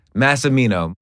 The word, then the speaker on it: Massimino: Massimino.